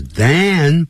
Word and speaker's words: than: 好，Tom was older than，本来叫 than，可是呢。他念弱音的时候他念 then 或者是 then、或者是念 then then, then, then, then, then, then, then, 不会念成 van。